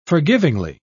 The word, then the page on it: forgivingly: British English